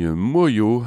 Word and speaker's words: Manglo: Ça s'écrit Manglo, M-A-N-G-L-O, et ça se dit Moyo, un chanteur de Saint-Vincent-sur-Oust, avec une belle chanson.